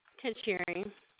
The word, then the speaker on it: Kacherri: Kachiri. – How did you spell it? – K-A-C-H-E-R-R-I.